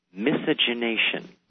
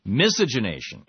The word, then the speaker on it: miscegenation: The pronunciation is miscegenation. – Miscegenation. – Yes. – Okay, thank you. – The final Southern word. – Okay, and you are the final authority on it. – Indeed. – And the emphasis is on the first syllable. – Yes, miscegenation, like the English.